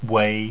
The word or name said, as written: wei